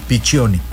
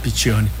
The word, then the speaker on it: Piccioni: ¿Cómo se pronunciaría? Piccioni. – Bueno. Como si fuera CH. Piccioni. – Ok, Piccioni.